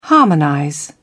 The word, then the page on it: harmonize: British English